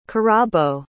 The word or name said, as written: kirabo